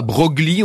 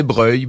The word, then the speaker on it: Broglie: On disait pas Broglie, on disait Breuil.